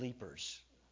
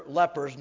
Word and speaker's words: lepers: The only thing is he didn't know how to pronounce the word lepers. And so he preached a whole message on the 10 leapers.